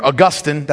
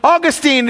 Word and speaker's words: Augustine: Augustine, or Augustine, that's the wrong pronunciation, it's Augustine.